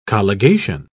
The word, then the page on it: colligation: British English